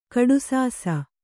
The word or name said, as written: ಕಡುಸಾಸ